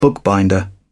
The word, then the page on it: bookbinder: British English